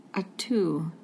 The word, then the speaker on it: at'u: And this at'u, am at'u. I saying it right? At'u. – At'u.